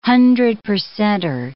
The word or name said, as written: hundred-percenter